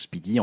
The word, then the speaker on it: SPDY: HTTP2, techniquement, c'est en construit partie sur un travail qui a été effectué par Google qui s'appelait SPDY, donc on prononce Speedy,